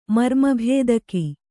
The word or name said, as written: ಮರ್ಮಭೇದಕ